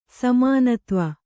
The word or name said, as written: ಸಮಾನತ್ವ